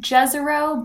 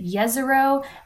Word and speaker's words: Jezero: We pronounce it Jezero, but I've heard that folks in the Bosnia-Herzegovina area say Jezero.